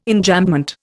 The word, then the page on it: Enjambment: Indian